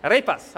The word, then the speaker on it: arepas: Do you roll the R on arepas? Ah, there's confusion today. Arepas, is that better? No, arepas. Good, arepas, okay.